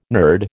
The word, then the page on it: nerd: American English